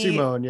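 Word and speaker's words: Simón: but then because I feel like I'm saying it wrong, Simon Bolivar died. He Simone, yeah. – Simone. Oh, well, I said it wrong 18,000 times then.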